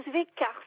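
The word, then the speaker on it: Svejcar: Alors, mon nom, c'est Zvekar. Ça s'écrit S-V-E-J, comme Joël, C-A-R.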